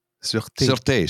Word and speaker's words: Sûreté: Did you just call it, say the Sûreté? – Sûreté, sorry. Hey, the pronunciation was good.